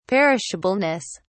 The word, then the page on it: perishableness: British English